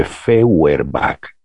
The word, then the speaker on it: Feuerbach: Feuerbach, como no estoy compartiendo pantalla, como no tengo aquí a mano una pizarra, Feuerbach se escribe Feuerbach, EU en alemán suena hoy,